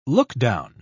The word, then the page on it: lookdown: British English